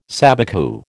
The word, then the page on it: sabicu: American English